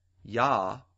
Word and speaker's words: Jah: And the KJV will spell it J-A-H. But back then, the J was pronounced as a Y, just like in the word Hallelujah. Hallelujah is spelled at the end of the word J-A-H, but it's pronounced with a Y sound. If you have a New King James Version, Psalm 68:4 will actually read Y-A-H.